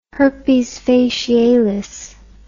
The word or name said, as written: herpes facialis